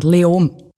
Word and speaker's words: Leoben: Eigentlich fast kein Konsonant. Leom.